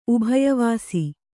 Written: ಉಭಯವಾಸಿ

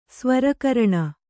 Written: ಸ್ವರಕರಣ

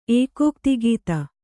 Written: ಏಕೋಕ್ತಿಗೀತ

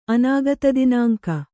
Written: ಅನಾಗತದಿನಾಂಕ

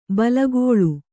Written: ಬೇಲಿಕಳ್ಳಿ

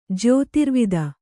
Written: ಜ್ಯೋತಿರ್ವಿದ

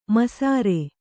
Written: ಮೈಸಿರಿ

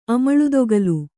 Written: ಅಮಳುದೊಗಲು

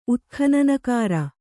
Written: ಉತ್ಖನನಕಾರ

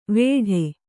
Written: ವೇಡೆ

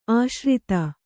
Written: ಆಶ್ರಿತ

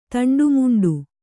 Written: ತಂಡಾಮುಂಡಾ

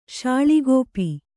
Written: ಶಾಳಿಗೋಪಿ

